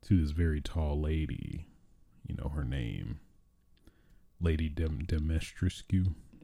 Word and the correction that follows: Dimitrescu: I'm totally butchering that. Lady Dimitrescu. That's what I'm going to say. Lady Dimitrescu.